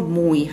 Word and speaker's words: Moi: Wie spricht man euren Laden eigentlich aus? – Moi. – Moi. – Genau. Moi wie das holländische Schön.